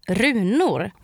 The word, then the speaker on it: runor: And this time the word is runor, which means runes. And it's spelled R-U-N-O-R.